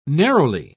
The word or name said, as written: narrowly